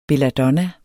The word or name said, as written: belladonna